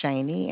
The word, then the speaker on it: Shaney: My name is Shaney, and I do spell that S-H-A-N-E-Y. – S what? – S-H-A-N-E-Y. – S-H-E-Y. – S-H-A-N-E-Y.